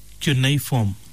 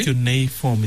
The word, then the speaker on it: cuneiform: The word we were looking for, Morana, is the word cuneiform. – Okay. – Cuneiform. It's spelled C-U-N-E-I and then form, F-O-R-M, cuneiform.